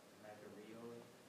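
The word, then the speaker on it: makarioi (μακάριοι): So there's a word that's pronounced makarioi. – Yes. That's the word for blessed.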